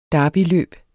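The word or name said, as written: DERBYLØB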